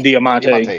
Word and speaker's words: Diamante: Diamante? Did I say it right? – Diamante.